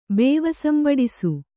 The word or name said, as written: ಬೇವಸಂಬಡಿಸು